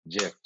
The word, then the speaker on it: GIF: And his acceptance speech was just, it's pronounced, and then J-I-F. It's pronounced GIF.